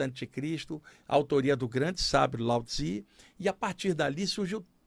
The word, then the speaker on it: Lao Tzu: Eu aprendi que não era Lao Tse ou Lao Tzu, era Lao Tzu.